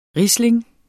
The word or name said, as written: riesling